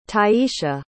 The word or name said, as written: tiesha